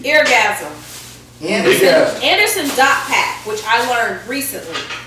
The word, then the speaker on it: Anderson .Paak: The dot is pronounced. He said he took so much time as being the underdog, nobody paying attention to him. He wants you to pronounce the dot. So it is Anderson Dot Pack.